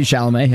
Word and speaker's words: Chalamet: Timothy Chalamet, yeah.